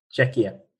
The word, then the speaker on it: Czechia: I don't know about what you pronounce it. Czechia.